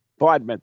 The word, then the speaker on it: Bodmin: Bowen, was it? – What? – Of Bowen, it's up in the UK, Devon, I think. – Bodmin? – Bodmin, thank you. See? – The Beast of Bodmin Moor.